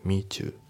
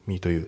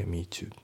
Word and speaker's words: meet you: で発音ですけど meet you のところがリンキングで meet you だね。 meet you じゃなくて meet you。